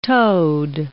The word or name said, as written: toed